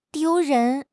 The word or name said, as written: 丢人